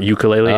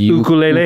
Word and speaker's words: ukulele: I always probably say this wrong because in like Hawaiian, the U is an U sound. Like it's not ukulele. – Oh, it's ukulele.